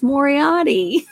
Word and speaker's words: Moriarty: It's Moriarty.